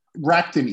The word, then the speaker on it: Rakuten: Rakuten Eagles is probably the most common. The U is kind of, you just pretend like the U is not there, but it was close enough.